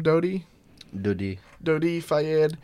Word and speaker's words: Dodi Fayed: Dodi Fayed.